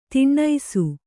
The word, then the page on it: ದಿನಸಿ: dinasi